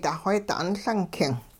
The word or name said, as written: duxweedi 'unLung king